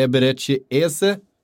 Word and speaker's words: Eberechi Eze: Har värvat eh, Eberechi Eze. Om det ska uttalas så. Ska det uttalas så Frida? – Ja, det låter bra.